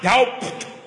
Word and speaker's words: doubt: D O U T, right? Huh? What is that bee doing in there? It's not doubt.